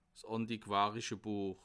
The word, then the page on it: 's àntiquàrische Buech: Bas-Rhin; Haguenau